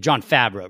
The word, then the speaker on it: Favreau: You just happen to throw the accent on, or like the emphasis John on the Favreau. Wrong syllable. Yeah, John Favreau, right.